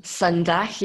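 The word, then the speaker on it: sonas: She's a founder of something called Sundag. Is that how I pronounce it? – Sundag, yeah, that's right. Yes, a Scottish word, Sundag.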